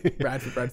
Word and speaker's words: Radford: Some people pronounce it Redford. You know, Radford.